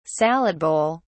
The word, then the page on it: salad bowl: British English